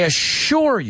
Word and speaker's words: assure: And, well, Sherman Klump says, I assure you, te aseguro. Dilo conmigo. Tiene doble S, pero se pronuncia como si fuera SH. Right? I assure you, te lo aseguro, I assure you I will not let you down.